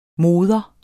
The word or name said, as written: mor